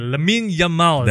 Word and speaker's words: Lamine Yamal: I mentioned Lamine Yasal. I think there was some autocorrect typo. – I'm very sorry about that. But yes, let me get his name right again. Lamine Yamal.